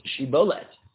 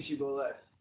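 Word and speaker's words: Shibolet (שיבולת): Anyone who can't pronounce Shibolet. Right? – You mean Shibolet. – What? – I'm dying. – No, it's only the only relevant word here is the shin.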